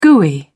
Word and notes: gooey: British English

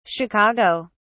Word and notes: chicago: British English

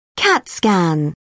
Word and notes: cat scan: British English

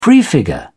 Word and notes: prefigure: British English

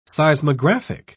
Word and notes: seismographic: British English

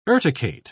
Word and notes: urticate: British English

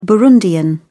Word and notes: burundian: British English

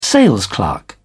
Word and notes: salesclerk: British English

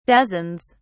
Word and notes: dozens: British English

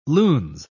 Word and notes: lunes: British English

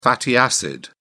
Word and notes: fatty acid: British English

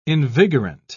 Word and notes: invigorant: British English

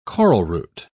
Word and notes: coralroot: British English

